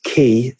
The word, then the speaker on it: Quay: They've also got CoreOS Key, spelled Q-U-A-Y, as in key, what's the right word?